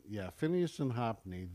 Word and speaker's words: Hophni: So when so what happened is that basically the sons of Eli, and I can't pronounce it's Phineas and Hophni. – Yeah, Phineas and Hophni.